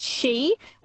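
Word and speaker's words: sidhe: They thought the she, it's spelled S-I-D-H, but it's pronounced she, which are Irish fairies, would come and like reveal mysteries and unlocked worlds and stuff.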